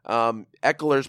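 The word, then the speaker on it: Ekeler: Eckler's playing well. I don't give a shit how you think I say his name. It's correct.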